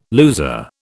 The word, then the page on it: loser: British English